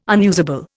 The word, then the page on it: unusable: Indian